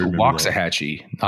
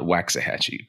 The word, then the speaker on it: Waxahachie: So, folks, yes, it's Waxahachie, not Waxahachie.